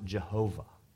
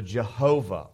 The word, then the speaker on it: Jehovah: We oftentimes pronounce it Yahweh. You may have heard that before, Yahweh. In the UK, oftentimes, especially some of the old scholars, pronounce it Jehovah. So Jehovah or Yahweh, this is the proper name of God.